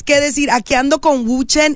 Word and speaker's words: Wu Chen: Wu Chen, es W-U-C-H-E-N.